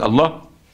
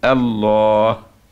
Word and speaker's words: الله: يعني ما تقول الله، الألف الأولانية ظهرها الله ليه؟ للعالمية. ولكن إيه الألف اللي قبل الهاء؟ الله محذوف، فهمت إزاي؟ بتنطقها لكنها محذوف، تكتب لم هي، في حين أنك كده تقول الله كأنك بتنطق ألف قبل اللام.